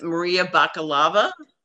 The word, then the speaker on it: Bakalova: So it's Maria Bakalova. That's how she pronounced her name the other night on Colbert.